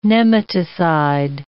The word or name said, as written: nematicide